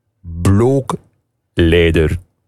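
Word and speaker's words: Blåkläder: Schrijf Blaklader, maar de uitspraak is niet Blokledder, maar Blokleider. Blokleider. Zeggen ze daar.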